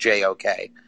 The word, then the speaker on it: JOK: JOK, and that's how you pronounce it, by the way. It's pronounced JOK.